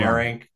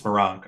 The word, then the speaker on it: Meronk: Is that how you pronounce it, by the way? I've been trying to figure out how to say that guy's last name. – Who? – Meronk? Or is yes, it Meronk. Meronk? It's Meronk. Okay.